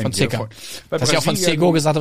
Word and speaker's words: Fonseca: Ja, der wird anders ausgesprochen wie er. – Fonseca. – Fonseca.